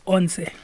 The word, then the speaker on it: Once: How did you pronounce, I mean, I used to say once Caldas, but obviously you have a special way of calling it. – It's Onse. It's 11.